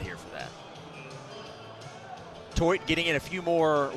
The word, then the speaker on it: Theut: Toit is spelled T-H-E-U-T, Dylan Toit.